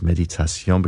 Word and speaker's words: Méditation: Or I should pronounce it in French, of course, Méditation, because this is from French composer Jules Massenet, and it's one of his most famous pieces, if not the most famous.